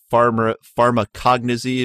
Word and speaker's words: pharmacognosy: because I would imagine in the pharmacognosy, am I saying that right? – Yep.